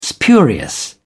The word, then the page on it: spurious: British English